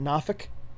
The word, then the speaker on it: Norfolk: Norfolk, Virginia. People there pronounce it Nafik.